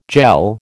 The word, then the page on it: jell: American English